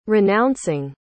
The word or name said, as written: renouncing